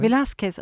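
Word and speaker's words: Velázquez: I won't pronounce his name correctly Valquez Velasquez. – Velasquez oh yeah yeah oh,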